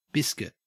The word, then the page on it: biscuit: Australian English